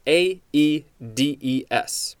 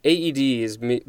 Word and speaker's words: Aedes: It is spelled A E D E S. It looks like the A and the E are both separate syllables, so this is Aedes.